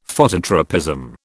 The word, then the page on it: Phototropism: British English